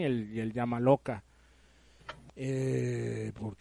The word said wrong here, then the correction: Yamaoka: qué el Yamaoka no se ve?